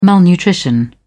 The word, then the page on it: malnutrition: British English